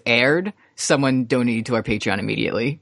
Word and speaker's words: Patreon: We have a Patreon patron. That's how you say it.